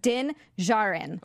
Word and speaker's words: Djarin: Sorry, I was just going to say it's pronounced Din Djarin.